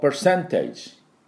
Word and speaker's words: percentage: Large percentage。 Large percentage。ということで、これで大部分、かなりの、大きな割合でと、いうことになるわけですね。また確率でと、かなりの確率でと。これ、ぜひものにしていただきたいと思いますが、この percentage。これ per のところはですね、半母音なんで、ちょっとだけでいいんです。 percentage。ここにアクセントがあります。